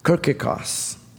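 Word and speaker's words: kirkikos (κυριακός): Our English word that relates to church as his is the Greek word, which is pronounced kirkikos, which means belonging to the Lord.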